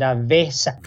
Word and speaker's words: Vesak: And it's to celebrate Vesak. – Yeah, we actually pronounce it Vesak.